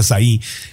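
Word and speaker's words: açaí: Oye, el azaí que se puso, eh, se escribe ACAI, pero es de Brasil, entonces se pronuncia azaí, que estuvo muy de moda.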